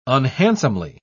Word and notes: unhandsomely: British English

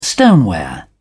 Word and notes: stoneware: British English

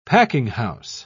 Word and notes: packinghouse: British English

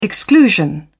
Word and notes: exclusion: British English

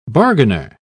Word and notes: bargainer: British English

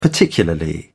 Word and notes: particularly: British English

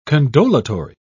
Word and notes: condolatory: British English

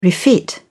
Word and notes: refit: British English

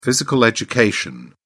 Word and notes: physical education: British English

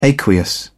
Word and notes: aqueous: British English

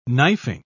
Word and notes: knifing: British English

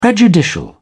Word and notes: prejudicial: British English

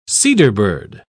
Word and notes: cedarbird: British English

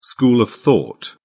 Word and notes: school of thought: British English

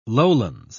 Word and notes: lowlands: British English

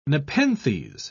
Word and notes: nepenthes: British English

